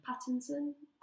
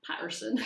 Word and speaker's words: Pattinson: Is it Pattinson? – Pattinson? – Oh, God, I've been pronouncing that wrong as well. – Why did I say Patterson? – Robert Patterson.